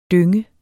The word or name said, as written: dynge